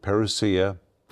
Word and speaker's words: parousia: That word coming is the Greek word parousia. And the word parousia, some people pronounce it parousia.